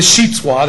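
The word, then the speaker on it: Xitswa: All right, now remember last week, this is the Sheetswa. That's, you say it, the X is pronounced sh, like SH. Okay, so that's how you can see it.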